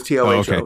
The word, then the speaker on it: Toho: Uh, it, it's pronounced the same way but longer. It's more like Toho.